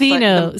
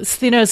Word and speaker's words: Stheno: Stheno. Stheno.